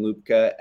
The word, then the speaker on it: Lubka: So I would just search my full name on Twitter, Steven Lubka, L-U-B-K-A.